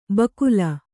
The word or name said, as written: ಬಕುಲ